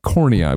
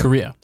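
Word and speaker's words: Corriea: Korea, as in the country. – No, as in the eyeball. No? – There's no end in the middle. Korea. Korea. Korea.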